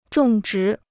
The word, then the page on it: 种植: zhòng zhí